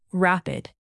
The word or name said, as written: rapid